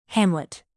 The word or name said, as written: hamlet